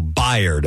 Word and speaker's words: Bayard: So Bayard, okay, is how you pronounce his name.